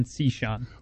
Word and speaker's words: Cishan: John Cishan. – Okay, we're going to fix your mic. John Cishan. Like C. Sean.